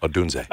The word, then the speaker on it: Odunze: I love the versatility of Odunze, I think is how you say it. Mm-hmm. I don't know. – Yeah, that's it. Odunze.